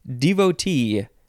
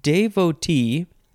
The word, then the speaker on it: devotee: You can pronounce it devotee, devotee, devotee. So there's de, d, day, and then duh, devotee. And then you can also pronounce the last syllable te devotee, devotee, devotee, devotee, all those different ways.